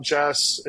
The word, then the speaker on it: Jas: Will you tell the audience a little bit about Jess and if I'm even saying that correctly? – Yeah, you are.